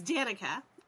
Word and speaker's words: Danica: I hope I pronounced that right. – It's Danica.